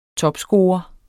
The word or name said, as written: topscorer